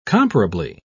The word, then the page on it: comparably: British English